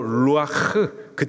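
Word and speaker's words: 루아흐 (רוח): 여기서 말하는 생기, 히브리식 발음으로 루아흐, 그 뜻은 생기, 호흡 하는 호흡, 그리고 바람, 그리고 성령이라는 뜻을 갖고 있습니다.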